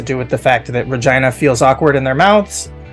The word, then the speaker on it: Regina: It's Regina. The easy tip is rhymes with vagina.